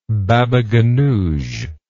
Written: baba ghanouj

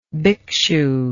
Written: bhikshu

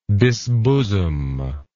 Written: disbosom

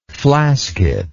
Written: flasket

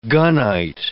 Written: gunite

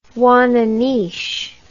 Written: ouananiche

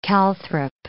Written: calthrop

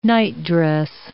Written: nightdress

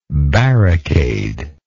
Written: barricader

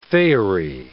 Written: faery

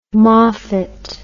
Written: Moffat

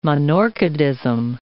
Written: monorchidism

